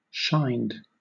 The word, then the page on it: shined: British English